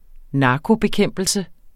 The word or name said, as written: narkobekæmpelse